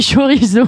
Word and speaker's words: chorizo: D'abord, on dit chorizo. Chorizo Fafner, si vous voulez.